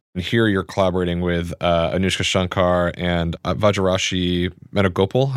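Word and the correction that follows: Varijashree: Varajashri Venugopal, I think is how you say it.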